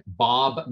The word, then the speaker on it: Bob: You can reach me at Bob Madden. That's B-O-B.